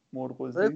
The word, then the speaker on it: مرغزی: نه مرغزی پوری توضیح داد و گفت مکانی اما تلفظش مرغزیه و مرغزی یا چیه بعد به چی رب داره خیلی توضیح.